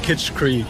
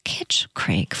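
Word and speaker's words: Kitschkrieg: The American audience doesn't quite know yet is how to pronounce our name. It's Kitsch Creek. Say it with me. – Kitsch Creek. – Yeah. – Oh, fire. – Listen and repeat. Kitsch Creek.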